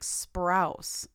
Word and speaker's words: Sprouse: I didn't realize their last name rhymes with spouse. It's like Sprouse.